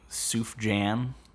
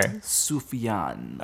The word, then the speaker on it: Sufjan: Sufjan. – Yeah, I was never 100% sure. – Sufjan.